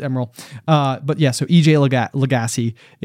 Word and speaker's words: Lagasse: Lagasse. So Emeril Lagasse is the original.